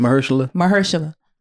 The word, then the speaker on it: Mahershala: I do want to say that I think Mahershala, is that how you say it? – Mahershala.